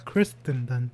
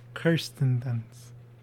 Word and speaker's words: Kirsten: Kirsten Dunst. Not Kristen Dunst. Kirsten Dunst. Who we know from... – I've been saying it wrong my whole life, apparently. – Yeah, it's not Kristen. It's Kirsten. Which is annoying, I think.